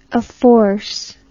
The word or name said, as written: afforce